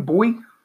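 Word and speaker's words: Bouye: How do you say his last name? Boye.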